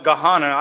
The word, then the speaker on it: Gahanna: Well, some people call it Gahanna.